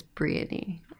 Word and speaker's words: Brienne: But it's Brienne, like peony.